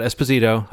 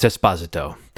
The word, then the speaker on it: Esposito: And yes, we've all been saying it wrong. It's not Esposito, I found out this week. It's Esposito.